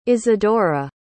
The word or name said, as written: isidora